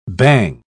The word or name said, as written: bang